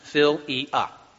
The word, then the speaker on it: philia: The word is philia. P-H-I-L-I-A. Philia. And that's how it's pronounced, phil-e-a.